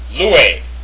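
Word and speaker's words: loue (λύει): All right, so number one, it's pronounced loue. Loue, the Koine Greek form, not word.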